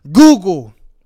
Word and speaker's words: googol: No, I'm talking about the actual mathematical term Google. It's spelled G-O-O-G-O-L.